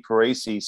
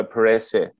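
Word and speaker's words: Perese: So the correct pronunciation, well, not the correct pronunciation, the way that it should be pronounced according to Moana is like you put an H after the E all the way. So Parese, basically. So Izzy Parese.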